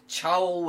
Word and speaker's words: Chaol: And his name was, I hope I'm pronouncing this correctly, Chaol Westfall. C-H-A-O-L.